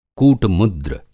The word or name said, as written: कूट-मुद्र